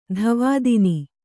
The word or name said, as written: ಧವಾದಿನಿ